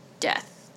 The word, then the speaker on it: debt: So Lucy is in Mary's debt. Yeah, that one. – Debt. It's a silent B.